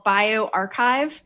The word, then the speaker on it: bioRxiv: So there is something called BioArchive. It's spelled BIORXIV.